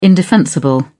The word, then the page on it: indefensible: British English